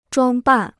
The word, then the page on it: 装扮: zhuāng bàn